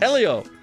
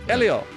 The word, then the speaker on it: Helio: Elio. – Elio. – Elio. – Elio. – Silent H. Elio.